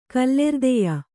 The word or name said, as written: ಕಲ್ಲೆರ್ದೆಯ